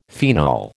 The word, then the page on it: phenol: American English